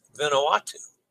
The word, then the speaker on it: Vanuatu: Also with Vanuatu.